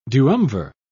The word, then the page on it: duumvir: British English